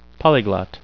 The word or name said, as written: polyglot